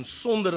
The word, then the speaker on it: sonder: S-O-N-D-E-R. So it's in sonder in Afrikaans, but you now pronounce it sonder in English.